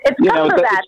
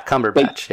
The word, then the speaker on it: Cumberbatch: They did it with Benedict Cumberbatch. Am I saying that right? I can never say his name right. And now, correct me, please. – It's Cumberbatch, isn't it? – Yeah, Cumberbatch.